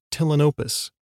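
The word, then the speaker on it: Ptilinopus: All of them are in the genus Tillinopus. That's with a silent P, like pterodactyl or ptarmigan.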